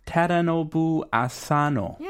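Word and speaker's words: Tadanobu Asano: I cannot pronounce his name properly. – Uh-huh. – 테라노부 아사노. – Yeah, I think you're right. 어, 맞는 것 같아요, 발음이.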